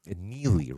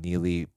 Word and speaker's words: Neagley: Neely, right? You don't pronounce the G. Him and Neely partnered up together.